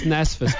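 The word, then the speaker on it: NSFAS: Andy, you're a recipient of NASFAS, or as people would say, NFSASS. – I hate it. I hate that one. – Pronounced NASFAS, by the way, just so you know. We're broadcasting from a university. We know it's NASFAS, not NFSASS.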